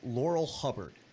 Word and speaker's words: Laurel Hubbard: Laurel Hubbard, I believe we got the name right this time around.